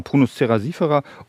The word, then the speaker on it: Prunus cerasifera: Sie hat geschrieben, wir haben letztes Jahr im Herbst eine Prunus cerasifera. Ist das richtig ausgesprochen? Mhm. – Genau, Prunus cerasifera.